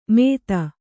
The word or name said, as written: ಮೇದ